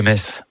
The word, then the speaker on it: Metz: Le meilleur moyen de mémoriser la prononciation correcte de la ville de Metz est certainement de se rendre au stade Saint-Symphorien, où vous répéterez jusqu'à plus soif.